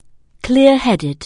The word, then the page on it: clear-headed: anglais